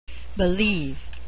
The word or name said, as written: believe